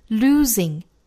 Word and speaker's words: losing: The act of losing, however, the act of, 连读 the act of losing, losing 这个词中间的这个 s 要读成 z, 浊辅音震动感 z, losing, losing, however, however, 这个 however 不要读成了 however, 应该是英式的小刷音作为尾音的 however, can elicit great wisdom, can elicit, 连读 Can elicit, can elicit, 大家多读几遍哈，连贯起来。